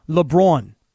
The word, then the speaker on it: LeBron: I believe that we have all been pronouncing LeBron's name wrong for all these years, and I'm going to try and use the Stephen A. pronunciation all season long. – LeBron? – LeBron. LeBron, B-R-A-W-N, LeBron.